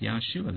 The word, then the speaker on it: Yahshua: Just as they had a man named Joshua, which Hebraically is pronounced Yahshua, the exact same way as their Savior's name.